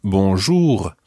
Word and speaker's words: bonjour: For English native speakers, the R at the end of bonjour is quite tricky to pronounce, and we'll be spending more time on that in a later lesson. Try to get something similar to it for the time being.